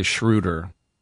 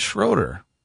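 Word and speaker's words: Schröder: People say Schroeder. – What is it? – It's Schroeder. – Is it really? – There's an umlaut there.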